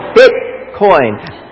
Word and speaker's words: Bitcoin: How many of you have invested in Bitcom? You know, no. Did I even say it right? Bitcom. Anybody, is it com or con? Coin. Bitcoin.